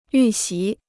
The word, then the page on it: 预习: yù xí